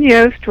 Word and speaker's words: Yoest: Helen Yoest runs with most.